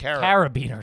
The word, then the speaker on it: carabiner: I was expecting you to have a, and I always say this wrong, a caribou. – Carabiner. – A carabiner, yeah. – He says caribou.